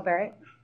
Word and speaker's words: Barrett: Michelle Barrett.